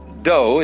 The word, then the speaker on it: Do: Do is the way the Japanese pronounce Dao.